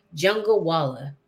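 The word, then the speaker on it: Jungalwala: Jungawala. Okay. So just like it's spelled, pretty much. Okay. – Yes. Think jungle and then Walla at the end.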